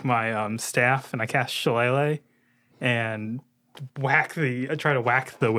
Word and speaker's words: Shillelagh: I love so much how you pronounce Shillelagh as Shillelagh. – It's Shillelagh. That's what it is.